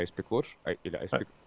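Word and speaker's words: Ice-Pick Lodge: Я правильно называю вообще Icepeak Lodge? А куда еще ударение поставить? – Icepeak Lodge, да.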